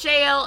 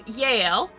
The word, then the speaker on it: Jael: And his wife, some pronunciations have it Jael and some of it is Yael, was not down with that.